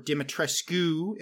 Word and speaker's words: Dimitrescu: Marty, can you give us some thoughts on when you first went to Castle Dimitrescu, or Dimitrescu, as the Romanians apparently really pronounce it.